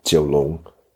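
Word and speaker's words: Jiulong: The Jiu in his name refers to Kowloon, which in Mandarin is pronounced Jiu Long, Nine Dragons.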